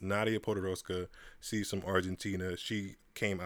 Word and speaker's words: Podoroska: Another player I wanted to mention that me and Brian didn't get a chance to talk about was Nadia Podoroska. A lot of people have been butchering her last name. To me, it's not that hard. It's not Ponderosa. It's not pork chop. It's not none of that stuff.